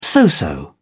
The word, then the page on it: so-so: British English